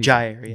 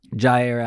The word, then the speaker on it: Jaire: Is it Jair or Zai? How do you Jair. Yeah. Jair. Jaire Alexander.